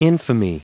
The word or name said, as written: infamy